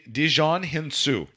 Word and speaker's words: Djimon Hounsou: G- Djimon Hounsou.